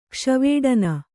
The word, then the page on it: ಕ್ಷ್ವೇಡನ: kṣvēḍana